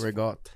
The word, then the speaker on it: ricotta: This Rigotte. That's how Italians say it. Maybe Brooklyn Italians say Rigotte. – Also, I've read a study that people from Naples drop the last syllable.